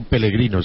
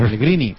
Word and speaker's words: Pellegrini: Tengo que decirle que ha pronunciado mal el su apellido. Ha dicho Pellegrino, señor. – Pellegrini.